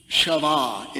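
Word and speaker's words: Shavah (חַוָּה): Shavah is the Hebrew pronunciation.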